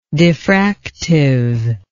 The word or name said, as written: diffractive